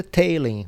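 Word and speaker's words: Talen: Todo mundo falou assim, Aprendi assim, que, que se pronuncia Taylen o nome dele, porque lá nos documentos que a gente recebe da NBA, quando tem nome que é estrangeiro, eles colocam é, a pronúncia.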